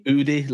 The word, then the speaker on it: Oodie: Udi. Like O-O-D-I-E. Yeah. Udi. Go find yourself an Udi, courtesy of Adam Taylor.